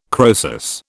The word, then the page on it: Croesus: British English